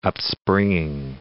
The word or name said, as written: upspringing